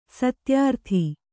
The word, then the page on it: ಸತ್ಯರತ: satya rata